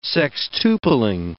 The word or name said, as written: sextupling